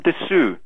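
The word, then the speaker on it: Des (です): Watashi wa Jim Desu from Beckenham. – You don't say Desu, you say Des.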